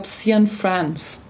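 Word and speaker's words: Cianfrance: Ich glaube, Cian France wird es ausgesprochen. – Wie schreibt man den? – C-I-A-N und dann France. – Wie in Frankreich.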